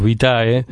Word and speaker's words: Vitae: Vitae. ¿Así se pronuncia? – Bueno, sí, en español decimos Vitae.